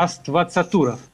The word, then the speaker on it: Аствацатуров: Авастацуров, а Аствацатуров.